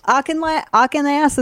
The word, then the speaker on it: Auchinloss: Auchinloss, is that his name? – Yes, you said that correctly.